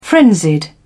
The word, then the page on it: frenzied: British English